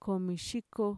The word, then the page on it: komishiko: Mixtec